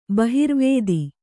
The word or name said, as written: ಬಹಿರ್ವೇದಿ